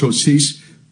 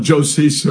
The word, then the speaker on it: Joses: But especially in the last verse of 15, we see that Mary Magdalene and Mary, the mother of, it's pronounced Joses, we call it Joses or whatever, observe where he was laid.